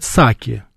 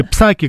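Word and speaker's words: Psaki: Я вот слушаю то, что говорит Саки, и я говорю, кстати, правильно ее называть Саки. Ее все Псаки говорят, но в английском языке выражение ее Пи Джейн Саки. Пи не читается, да.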